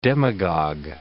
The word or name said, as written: demagog